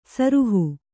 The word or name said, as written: ಸುರುಹು